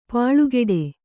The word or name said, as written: ಪಲ್ಕಡಿ